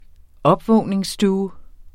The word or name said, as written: opvågningsstue